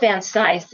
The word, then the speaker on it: Vancise: Philip Van Size, and it's spelled V-A. Well, here. Here's the book. Philip Sidney Van Size. Can you see that? Fighting the Underworld. And it's spelled V-A-N-C-I-S-E.